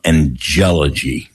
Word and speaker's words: angelology: We put the accent on the penultimate, not the ultimate, you know, so angelology is pronounced angelology, but all that said, I can say, just say over the years.